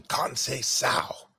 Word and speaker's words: Conceição: I think Shakur Stevenson puts it on Conceição and anybody else in that weight class. – Yeah, absolutely, and thank you for reminding me. Conceição. You got to hit that pronunciation.